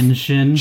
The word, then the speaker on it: Genshin: Genshin.